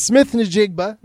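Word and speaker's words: Smith-Njigba: No, it's Smith Njigba. Not too hard.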